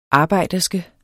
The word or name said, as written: arbejderske